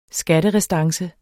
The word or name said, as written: skatterestance